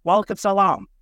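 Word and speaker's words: walaikum salam (وعليكم السلام): Am I saying it right walaikum salam, walaikum salam. That's how you say it in response to assalam walaikum.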